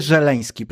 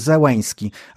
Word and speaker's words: Zełenski: Nie Żeleński, proszę pani, tylko Wołodymyr Zełenski.